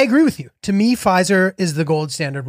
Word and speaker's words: Pfizer: Uh, and there's also something about a silent P that I just love. Pfizer. You want to say it, Pfizer, but you don't.